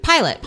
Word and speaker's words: Pilate: Orfei is Pilate. Is that how you pronounce it? – Pilate. Pontius Pilate. Pilate.